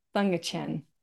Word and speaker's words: Thankachan: I just want to welcome today CEO of Lightyear, Dennis Thankachan.